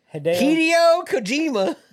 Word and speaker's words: Hideo Kojima: Just say it like a normal person. – Kojima-san. – Hideo Kojima. – There you go. Better. You're trying to put an accent on it. Like, don't.